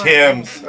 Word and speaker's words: Thames: Thames, like the Thames River. – Thames.